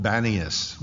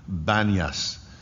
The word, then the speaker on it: Banias: And it's in the area that's called Panias, after the Greek god of Pan, the goat. You guys know Pan. And the people that live in that area today can't pronounce the P, so it's called Banias when you go there to Israel today. Banias.